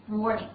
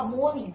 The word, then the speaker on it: morning: Morning 注意一下发音的时候，大家注意前面那个 or 卷舌，不要 morning OK，卷一下 or morning。